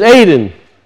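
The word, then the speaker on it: Aden (עדן): So we pronounce it Eden in English, but it's Aden.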